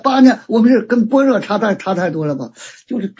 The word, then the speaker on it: 般若: 哦，那个若就是那个用一下就尼亚，就这个就那在古代一念就是巴尼亚，就这么念的。这就现在念成般若了。